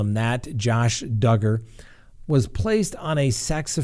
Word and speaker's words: Duggar: All right, next on the docket, Josh Duggar rhymes with hugger.